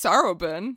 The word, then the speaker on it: Sarubin: So it's spelled S-A-R-U-B-I-N. So it's Sarabin.